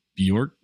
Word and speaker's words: Bjorkgren: And now, like, they're having a lot of the same struggles, and there's some weird, like, decision-making from Nate Bjorkgren, that's how you say his name.